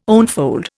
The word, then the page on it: onefold: Indian